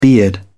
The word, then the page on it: beard: British English